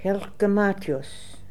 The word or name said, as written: Helkgematios